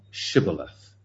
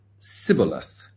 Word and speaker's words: Shibboleth: Then said they unto him, say now Shibboleth. And he said, Sibboleth. He's trying to say Sibboleth, he can't say it. Say now Shibboleth. And he said, Sibboleth, for he could not frame to pronounce it right.